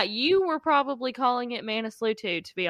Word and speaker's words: Manaslu: I think we said Manaslu in part one, and it's actually Manaslu.